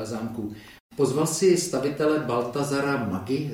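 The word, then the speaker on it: Maggi: Baltazar Mádži, pokud se nepletu, tak eh, s touto výslovností, tak eh, zkrátka Italové uprostřed čumaské přírody chtěli postavit to, co je v Itálii.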